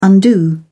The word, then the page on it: undo: British English